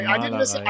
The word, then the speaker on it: Malerei: Malerei. – All right, there we go. Sorry, Malerei.